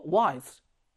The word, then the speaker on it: wise: 그리고 wise, 현명한 지혜로운. 그런데 이 단어 몇 음절인가요? wise, 삼음절 아니죠. 발음되는 모음은 그렇죠. 이 하나예요. 이거 이 발음되지 않잖아. wise 이렇게 안 읽죠.